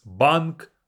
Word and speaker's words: банк: Bank is банк, move the А, банк.